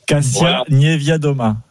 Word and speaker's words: Kasia Niewiadoma: Et surtout la bataille pour le général, la polonaise dont je n'arrive pas à prononcer le nom, Kasia Niewiadoma.